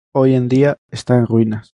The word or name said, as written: ruinas